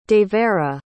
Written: devera

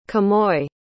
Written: quemoy

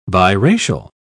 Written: biracial

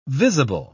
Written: visible

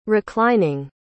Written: reclining

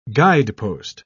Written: guidepost

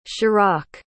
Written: Schirach

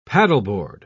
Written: paddleboard

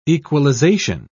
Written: equalization

